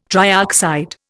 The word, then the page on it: trioxide: Indian